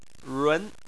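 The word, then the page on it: run: tone 2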